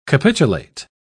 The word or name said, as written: capitulate